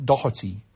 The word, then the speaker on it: Doherty: Sometimes I hear Doherty, other times I hear Doherty. Yes, well, in fact, neither's right. It's Ken Doherty.